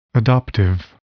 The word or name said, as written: adoptive